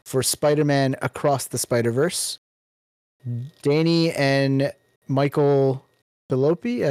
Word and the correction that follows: Philippou: I don't know how you say that. – There's no I. – Philippou for Talk to Me.